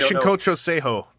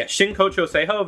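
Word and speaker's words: Shinkocchou Seihou: Shokaku Seisho? Like, I'm probably butchering it. – Shinkocho Seiho. – Yeah, Shinkocho Seiho.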